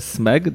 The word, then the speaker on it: Smeg: E, przekonałem się o tym, jest taka e, marka mm, e, sprzętu AGD SMEG, dobrze wymawiam?